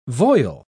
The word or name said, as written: voile